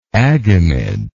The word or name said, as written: agamid